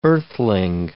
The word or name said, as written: earthling